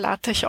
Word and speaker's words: LaTeX: Also LaTeX geschrieben, aber LaTeX ausgesprochen.